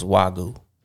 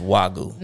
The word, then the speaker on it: Wagyu: So it's pronounced Wagyu. – No, it's not. Do it. – I'm looking them now. That whatever she just said. – Oh, that wasn't it. – Exactly. It's Wagyu.